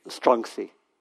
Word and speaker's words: Chuang-Tzu: I read last time quite a lot of from Chuang-Tzu, I think it's pronounced Chuang-Tzu. Chuang-Tzu is the, I was given some guidance on pronunciation. Okay. Chuang-Tzu. How it say? Chuang-Tzu. Yeah. It would be Chuang-Tzu. Is that close? It's close. Close enough.